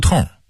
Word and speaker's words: 胡同: 元大都人就说胡同，胡同这个词儿是典型的北京话，很多南方人说不准胡同这俩字儿，很生硬的把胡同的同读成同志的同，其实它应该儿化韵，读痛，同发痛快的痛的音。